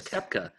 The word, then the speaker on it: Koepka: Is his name Rooks Bepka? – It rhymes with that, yes. Uh-huh. Brooks Koepka.